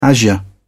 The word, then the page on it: azure: British English